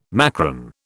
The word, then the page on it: macron: British English